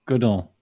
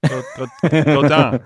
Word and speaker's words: Godin: So it's like the opposite of stay Dan, it's Godin.